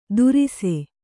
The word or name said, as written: ದುರಿಸೆ